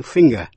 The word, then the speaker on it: finger: Sing, S-I-N-G, thì đọc như âm ng như trong tiếng Việt, chứ không phát âm là g như trong chữ finger. F-I-N-G-E-R là ngón tay. Phát âm là finger.